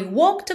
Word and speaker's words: walked: Atenção que é de walked. Não é pronunciado, tá? Você não diz walked, esconde totalmente o E, já vai do K pro D. E também nessa mesma palavra, esse começo W-A-L-K, a gente não fala como se lê, não é walk, walk, é walk, walk, ok?